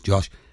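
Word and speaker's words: Jawsh: I always want to pronounce that wrong because it's spelled J A W S H. I think it's pronounced Josh.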